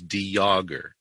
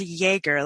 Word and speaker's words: de Jager: The pronunciation that we use is De Jager. It's a very silent J. De Jager. It's a French pronunciation, which fits in with the distant ancestry of ours. – Okay. Well, that makes sense. I know my pronunciation was coming from Jägermeister, so I don't know if you take that as a good thing or a bad thing. – I've been called everything under the sun. – I was like, I wonder if it's pronounced Jäger, like Jägermeister. – Nope.